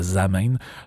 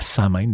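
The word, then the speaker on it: Samhain: Donc, la, la, ou la zamen, euh, ou la samène, dépendamment comment vous le prononcez,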